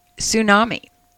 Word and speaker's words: tsunami: Tsunami. The T is silent. – Yes, it is. – Okay.